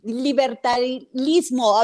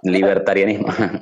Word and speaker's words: libertarianismo: Claro, y Iván ha estado estudiando mucho sobre un tema de libertali, libertarismo. A ver, lo dije bien, mm. perdón. – Libertarianismo.